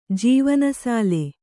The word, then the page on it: ಜೀವನಸಾಲೆ: jīvana sāle